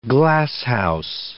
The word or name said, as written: glasshouse